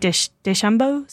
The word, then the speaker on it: DeChambeau: And weren't you saying earlier that now people do like DeChambeau? Is that how you pronounce it? – Yeah.